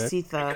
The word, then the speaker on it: Sithe: It's called Sithe. S-I-T-H-E. I guess that's how you say it. Or okay. Sitha. Okay.